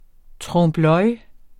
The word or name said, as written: TROMPE